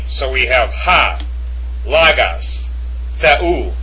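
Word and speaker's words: Theu (Θεοῦ): And it's pronounced Theu.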